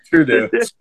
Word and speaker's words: Trudeau: Trudeau then. Trudeau. – was the best pronouncing of his name I've ever heard.